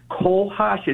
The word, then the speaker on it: cohosh: It's a black cohosh. It's spelled C-O-H-O-S-H.